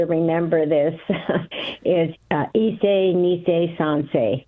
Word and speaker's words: Sansei: Is it Sansei? – Sansei. – Sansei. – Yeah, Sansei, S-A-N-S-E-I.